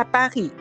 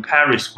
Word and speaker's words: Paris: J'étais à Paris, mais c'est très longtemps. – J'étais à Paris, mais c'était longtemps. – Yes. – 而这个巴黎其实发音时候不是我们想象的什么 Paris 或者巴黎，二它是发呵的音，叫巴黎。